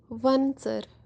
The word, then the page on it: wan-tser: Kashmiri